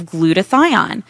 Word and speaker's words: glutathione: And before the break, your host, Rick Simpson, was talking about the benefits of glutathione. So, did I pronounce it right, Rick? – Yes, you did.